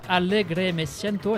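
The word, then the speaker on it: Alegre me siento: Et vous êtes venu avec donc un nouvel album, Allegre me siento, est-ce que je prononce bien? – Parfaitement.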